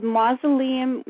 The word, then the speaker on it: mycelium: Is it mycelium? – It is, isn't it? – Well, that's how we say it over here. I don't know how you say it over there. – I have no idea. I'm just reading it the way it looks. – Yep, Mycelium Memoirs is what I've heard it said or pronounced. – There we go, then. You got it right, then.